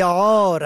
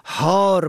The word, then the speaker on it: عار: و در کلمه عار عین الف ر به معنای ننگ اگر به هی دو چشمه تلفظ شود هار به معنای سگ دیوانه.